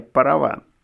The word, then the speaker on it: Параван: Но между собой торговцы стали называть разрешение «Параван» с ударением на среднюю «А».